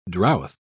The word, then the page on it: drouth: British English